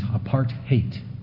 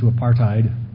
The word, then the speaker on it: apartheid: He says the proper pronunciation is apartheid. Apartheid. In our common American linguistics, we've turned it to apartheid.